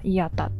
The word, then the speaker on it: IATA: IATA と書いて、まあ、イアタっていう組織がありますはい。